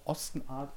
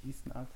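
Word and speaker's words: Osten Ard: Genau, von dem hier die schöne Ostenart. Karten hängt, was übrigens im Englischen auch Ostenart ausgesprochen wird, nicht Eastenart.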